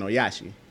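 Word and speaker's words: Ohashi: For example, Caitlin Oyaneshi. I hope I pronounced that name right. Oyashi, I'm sorry. It's Caitlin Oyashi.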